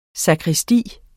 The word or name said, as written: sakristi